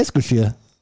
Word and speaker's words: Eskişehir: Und danach Eskişehirspor. Bestimmt falsch ausgesprochen. Ja. – Eskişehir.